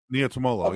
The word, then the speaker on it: Niumatalolo: Ken Niumatalolo, is that right? – Niumatalolo, yep. – I think it's Niumatalolo. Okay, Niumatalolo.